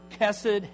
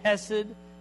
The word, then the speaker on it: hesed: This, this word, loyal love, loving kindness, steadfast love, is the key uh, uh, the key idea is it's the Hebrew word kesed, hesed is sometimes how it's pronounced.